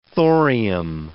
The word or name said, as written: thorium